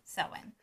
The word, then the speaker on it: Samhain: Often mispronounced as Samhain. It's Samhain.